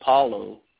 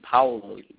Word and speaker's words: Paolo: Most people say like Paulo, but it should be Paolo. But you have to say it kind of with like the Latin flair, you know, to really say it right.